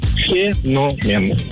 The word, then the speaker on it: феномен: На самом деле у этого слова есть два варианта ударения. Все зависит от значения. Итак, если это слово употребляется в значении что-то необычное, особенное, явление, редкий факт, да, тогда феномен. Все правильно, феномен.